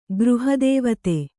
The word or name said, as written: ಗೃಹದೇವತೆ